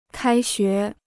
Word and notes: 开学: kāi xué

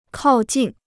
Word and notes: 靠近: kào jìn

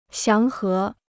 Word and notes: 祥和: xiáng hé

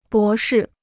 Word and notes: 博士: bó shì

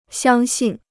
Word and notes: 相信: xiāng xìn

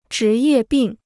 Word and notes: 职业病: zhí yè bìng

